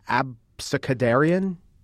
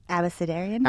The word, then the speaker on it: abecedarian: Abecedarian? – Abecedarian? – Abecedarian.